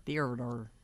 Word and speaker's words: theater: Theater. – God. People are so uptight. – It's like Theodore, just theater.